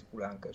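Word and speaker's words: Poulenc: non so se lo pronuncio bene, Polenk, eh, poi, Francis, Pulanca, certo. – Pulanca, scusate, eh, Kurt Bale, poi c'è Britten, Renzo Rossellini, eh, Richard Bennett e Shostakovich.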